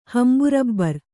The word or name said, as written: ಹಂಬುರಬ್ಬರ್